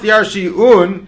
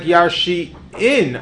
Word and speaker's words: yarshiun: We have a mesaira to pronounce it, yarshiun.